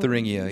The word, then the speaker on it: Thuringia: Thuringia, Thuringia. I believe that's how it's unfortunately pronounced.